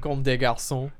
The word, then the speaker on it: Comme des Garçons: Comme des Garçons.